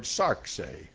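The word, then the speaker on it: sarx (σάρξ): it's from the Greek word sarxe. It is sigma, alpha, rho, xi. And you pronounce it sarx.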